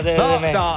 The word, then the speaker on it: Temecula: Temecula o Temecula? – Temecula. – Aspetta. Non è in Enk, è Temecula.